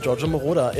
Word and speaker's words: Giorgio Moroder: Oder wie spricht man den aus? Sag mal. – Giorgio, ich glaube, das ist alles richtig. Giorgio Moroder.